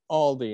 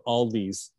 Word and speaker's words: Aldi: I prefer going to Aldi anyway because they're cheaper. I like the off brands. – We don't have Aldi around here. – Yeah, no. – Also, thanks for saying Aldi instead of Aldi's.